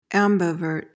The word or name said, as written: ambivert